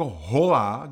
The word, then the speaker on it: hola: Jo, proč mám slova jako hola, kde mám jasný h, ale já ho prostě nemůžu vyslovit?